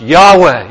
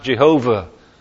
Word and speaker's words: Yahweh: The name nowadays is usually pronounced Yahweh. It used to be pronounced Jehovah. Nobody actually knows how it was pronounced because we only have the vowels, Y-H-W-H.